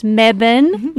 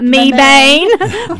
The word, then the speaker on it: Mebane: And that is pronounced Mebane, not Mebane, for all of you outsiders.